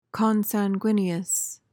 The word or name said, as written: consanguineous